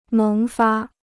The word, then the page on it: 萌发: méng fā